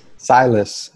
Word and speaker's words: Silas: Silas. – Silas, and I think his father might have coached or played in the NBA, so he kind of has NBA background.